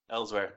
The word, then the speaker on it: Elsweyr: Elsweyr.